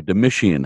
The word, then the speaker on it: Domitian: Domitian. Okay, Domitian.